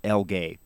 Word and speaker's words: LGHEI: And you say it's they pronounce it L-gay, but as John mentioned, it's the letters are LGHEI.